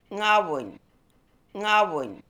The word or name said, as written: Ngaboony